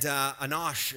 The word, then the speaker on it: Enosh: And Enosh is actually how you say it.